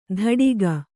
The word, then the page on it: ಧಡಿಗ: Kannada